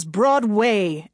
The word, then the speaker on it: Broadway: That's where I learned that an actor's highest calling was the theater, and the ultimate goal for a true thespian was Broadway, emphasis on the way.